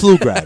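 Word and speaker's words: Pflugrad: On top of everything, it's not Ploof. – It's Flugrad, bro. The P is silent, right? This is the second year I've been telling you this. Her name is Amanda Flugrad. – Mark D'Amico can't do shit. – Don't pronounce the P, all right?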